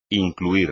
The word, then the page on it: incluir: espagnol